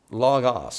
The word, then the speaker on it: Logos: It's called Logos, L-O-G-O-S. There's a very famous, well-known Bible study software, Logos. Some people pronounce it differently, but Logos is what it's called.